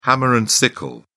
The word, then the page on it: hammer and sickle: British English